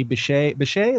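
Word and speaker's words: Bishé: Carrie Bechet, is that how we pronounce it? – Yeah. – There's an accent on that E, I believe.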